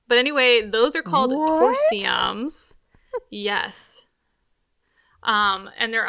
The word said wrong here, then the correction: tersoriums: But anyway, so yeah, tersoriums. I said that wrong originally. Tersoriums are those sponges.